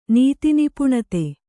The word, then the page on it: ನೀತಿನಿಪುಣತೆ: Kannada